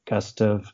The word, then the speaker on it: Gustav: Gustav, which I think most people pronounce in Gustav, and I did too until I met Gustav Stickley III, and he introduced himself as Gustav, and then I was like, oh, that's so interesting.